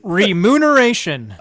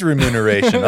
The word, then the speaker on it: remuneration: Remuneration. – Oh, it was remuneration. Okay. I thought so. – Say it right.